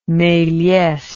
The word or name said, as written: melies